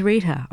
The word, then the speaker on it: Rita: My name is Rita, R-I-T-A, and Rochelle is spelled R-O-C-H-E-L-L-E.